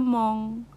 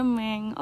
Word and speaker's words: Meng: Gitu tahu tadi btw tadi gue kira bacanya mong ternyata bacanya meng oke okay.